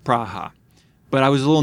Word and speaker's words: Praha: We're a big deal in Pragua. – Pragua. – Which is properly pronounced Praha.